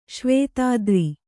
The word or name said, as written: ಶ್ವೇತಾದ್ರಿ